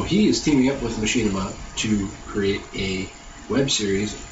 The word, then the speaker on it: Machinima: He is partnering up with Machinima. We agree that's how they say it.